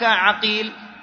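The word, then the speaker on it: عَقيل: الآن عندك عقيل، عين قاف يا لام، صحيح؟ هذا كتابته. وعندنا عقيل، عين قاف يا لام، صحيح؟ لكن الخط واحد. لما تأتي تنطق، إذا أردت أن تنطق الأول بالفتح والثاني بماذا؟ بالضم.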